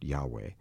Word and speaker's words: Yahweh: Oh, Adonai, remember God's name, in the God's proper name is probably pronounced Yahweh. In the King James Bible, they say Jehovah.